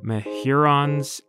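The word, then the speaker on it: Mehuron's: Mehurons or Mehurons. The difference is admittedly very minor. Maybe it's just a malleable word. I double-checked with Tom and his family, though, and they emailed me a written pronunciation. And so, Mehurons it is. Mehuron, as in Lake Huron.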